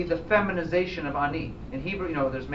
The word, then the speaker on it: ani: How do you say it in Hebrew? You know the word ani? You've heard this word before? Ani? The Hebrew word for I is ani.